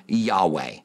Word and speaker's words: Yahweh: Most Hebrew scholars today, and I've talked to some, believe that it was pronounced close to Yahweh.